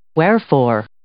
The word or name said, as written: wherefore